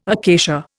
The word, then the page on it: acacia: Indian